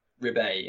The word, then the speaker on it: Ribet: So when you met him, his name is Paul Ribet, or Ribet, is it? Or Ribet, yeah. – Ribet, I got the French pronunciation right there.